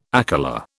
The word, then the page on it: akela: British English